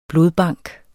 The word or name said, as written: blodbank